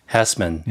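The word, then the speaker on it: Hesseman: The Chief, originally played by Howard Heisman, I think that's how it's pronounced. Hessman. – Yep.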